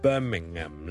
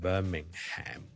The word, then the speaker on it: Birmingham: 如果你美国人咧，可能会读埋个 Ham 出嚟，Birmingham 咧就可能会读成 Birmingham 咁样，咁但系如果英国咧。大部分人呢个 HM 都系唔同嘅。